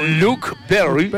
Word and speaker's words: Luke Perry: Luke Perry.